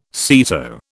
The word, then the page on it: SEATO: British English